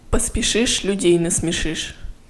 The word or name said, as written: поспешишь людей насмешишь